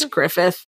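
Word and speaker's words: Gruffudd: I picture him more as an Ian Grufford. – Griffith? It's Griffith. – Is it? Okay. – It's spelled Griffud, but he's Welsh, so it's Griffith.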